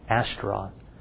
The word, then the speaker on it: Astaroth: Estera, no, that's not the correct pronunciation, Astaroth.